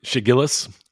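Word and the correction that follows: Shai Gilgeous-Alexander: It's Shai Gilgeous Alexander.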